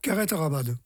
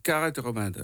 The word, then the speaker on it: carreto ramado: Alors, en provençal, c'est Philippe qui me l'a dit, hein, carreto ramado, c'est ça? – Carrette ramade. – Carrette ramade. Hein.